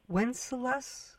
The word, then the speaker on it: Wenceslas: There are marvelous essays about Tahrir Square, Tiananmen Square, I don't know how to pronounce the Wenceslas Square in Prague. – Is that is that right? It is right.